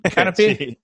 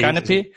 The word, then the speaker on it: Canopy: Es el eh, Canopy, ¿verdad? ¿Lo digo bien? Canopy. – sí, Canopy.